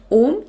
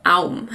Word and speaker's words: Aum: in diesem Zeichen sieht man, dass das Om eigentlich aus drei Silben besteht. Also aus A, U und M. Also eigentlich ist es nicht Om, sondern Aum.